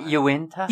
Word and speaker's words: Uinta: No, Isn't no, it Uinta? – Uinta. See, I knew I was saying it wrong.